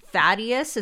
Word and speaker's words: Thaddeus: Thaddeus, is that how you pronounce that? – Yeah.